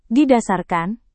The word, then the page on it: didasarkan: indonesian